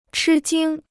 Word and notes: 吃惊: chī jīng

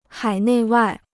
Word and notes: 海内外: hǎi nèi wài

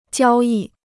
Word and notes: 交易: jiāo yì

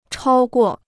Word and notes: 超过: chāo guò

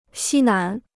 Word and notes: 西南: xī nán

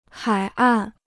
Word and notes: 海岸: hǎi àn

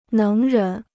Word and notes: 能人: néng rén

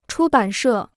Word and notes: 出版社: chū bǎn shè

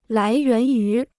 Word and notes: 来源于: lái yuán yú

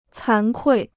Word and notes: 惭愧: cán kuì

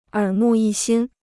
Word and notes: 耳目一新: ěr mù yī xīn

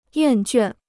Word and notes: 厌倦: yàn juàn

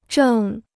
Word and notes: 正: zhèng

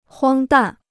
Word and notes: 荒诞: huāng dàn

